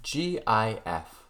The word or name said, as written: GIF